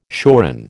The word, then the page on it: shoran: American English